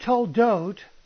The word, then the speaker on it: toldot: But the word toldot means the same thing if it is spelled T-O-L-D-O-T, and it's pronounced the same way if it's spelled T-L-D-T, right? If you remove the vowels, the consonants which serve as vowels, it's still pronounced toldot, T-L-D-T, because the O's are understood. That's the way the word is pronounced. So it's pronounced toldot if it's spelled T-L-D-T or T-O-L-D-O-T or T-L-D-O-T or T-O-L-D-T.